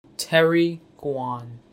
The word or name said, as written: Terry Guan